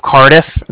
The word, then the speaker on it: Cardiff: And then I'm calling with the Bank of Cardiff, and that's spelled C-A-R-D-I-F-F, as in Frank, Frank. So it's like car and then different.